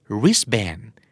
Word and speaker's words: wristband: wristband ก็อ่านว่า wristband ไม่มีตัวทีไม่ต้อง wrist band wristband